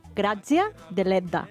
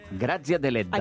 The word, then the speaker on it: Grazia Deledda: Grazia Deledda. – Grazia Deledda.